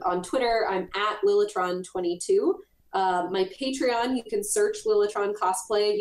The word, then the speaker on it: Patreon: Your I'm going to say it wrong again, your Patron, all your stuff. – Patreon.